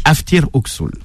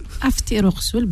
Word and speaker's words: Aftir Oksoul: Aftir Oksoul. – Aftir Oksoul, bien prononcé.